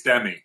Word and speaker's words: Demi: How do we pronounce her name? – I think it's Demi. – Demi Lovato. Yep.